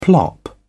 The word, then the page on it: plop: British English